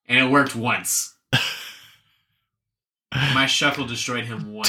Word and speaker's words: Shuckle: Rhymes with Uckle because his name is Shuckle.